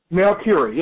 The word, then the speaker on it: Melchiorri: Okay, so you're on the show, and Anthony is it Melchiorri? Is that how you pronounce his last name? – Melchiorri, yeah.